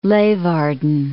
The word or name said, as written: Leeuwarden